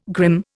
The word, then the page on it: grim: Indian